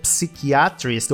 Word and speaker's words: psychiatrist: Psiquiatra que tem um certo probleminha, porque a gente vê aquela letra P no começo e a gente quer dizer psychiatrist ou psychiatrist, mas a gente não pronuncia o P, a gente fala a partir do S.